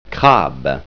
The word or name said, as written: crabe